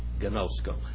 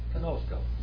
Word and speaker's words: gnosko: K-N-O-W. Now, why do we not say K? It's just know, isn't it? No. Because it comes from the Greek word. Gnosko. You don't pronounce that either. It's gnosko. It's gnosko.